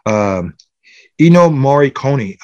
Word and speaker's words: Ennio Morricone: I think if they got his name is pronounced Ennio Morricone.